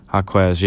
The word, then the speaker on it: Jaquez: So one thing that also stuck out to me was Jaime Jaquez. Is that how you say his name? – Yeah. That's close. Jaquez, yeah.